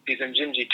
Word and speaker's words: Injinji: So these Injinji toe socks, how do you spell it? I-N-J-I-N-J-I, Injinji.